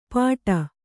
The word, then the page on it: ಪಾಟ: Kannada